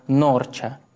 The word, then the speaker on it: Норча: Ныне название города произносится как Норча.